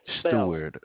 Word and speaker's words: Stewart: Stewart. – Spell it. – S-T-E-W-A-R-T. – S-T-E-A-W, what now? – A-R-T. – S-T-E-A-W-A-R-T.